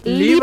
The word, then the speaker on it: Levi's: Muy bien, Borja, es Levi's.